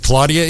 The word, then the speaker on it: Claudia: Claudia, is that how, did I pronounce that right? – Yeah, I think you got it.